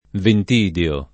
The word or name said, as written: Ventidio